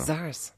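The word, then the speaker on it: Czars: Spricht man so aus, als ob da kein C wäre. – Zars.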